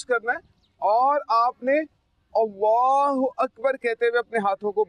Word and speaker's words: अल्लाहु अकबर: तो इसका जो प्रोनाउंस है अल्लाहु अकबर है, क्या है? – अल्लाहु अकबर।